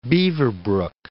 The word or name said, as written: Beaverbrook